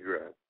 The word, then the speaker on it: Steffi Graf: Because I noticed that we have I have a song right here called Steffi Graf. Is that how you pronounce it? Steffi Graf. Yeah.